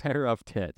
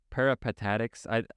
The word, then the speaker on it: Peripatetics: You said Peripatetics? Yeah, that's... – Peripatetics. – Yeah, there we go. – Peripatetics.